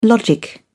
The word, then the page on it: logic: British English